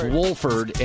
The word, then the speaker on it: Wolford: Pronounce the L. He said it's Wolford.